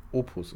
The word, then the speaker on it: opus: Ähm, Habe ich es wieder falsch ausgesprochen? Wie, wie nennt man es? Magnus? Ich glaube, aber ich habe auch kein Latein gehabt, aber ich glaube Opus und nicht Opum.